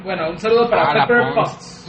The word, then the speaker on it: Pepper Potts: A Pepper Pons, mejor conocida como Edith de Juárez. – Saludos, Edith. – Ah, Pepper Potts.